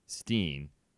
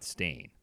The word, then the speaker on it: stain: pronounced it Steen, not Stain.